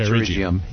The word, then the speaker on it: pterygium: Please provide a treatment protocol for pterygium. Did I pronounce that right? P-T-E-R. – Yeah. – Why? – Well, G-I-U-M. – Let's talk about pterygium.